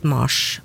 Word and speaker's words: Mars: Og det her kan være forklaringen på at Mars, navnet på måneden uttales med kortere A hos oss.